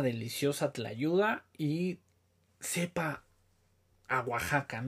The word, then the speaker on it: Guajacka: Eh, no Oaxaca como se escribe, sino G-U-A J-A-C-K-A, si no mal recuerdo, Oaxaca Brand así como se escucha.